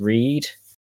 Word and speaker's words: Reid: Reed, when Reed spelled R-E-I-D for anybody that's going to misspell it, because everybody always does.